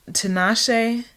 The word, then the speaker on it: Tinashe: Und ansonsten das Album von Tinashe. Wird sie so ausgesprochen? – Ja.